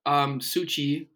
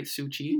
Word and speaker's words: Suu Kyi: So, Suu Kyi, that's how you say it, right? Suu Kyi.